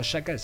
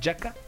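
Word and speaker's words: Xhaka: Buen partido en términos generales del medio campo entre Gendouzi eh, o y Yaka, que realmente así me dijo Sebastián Alarcón que se pronuncia, no Yaka sino Yaka.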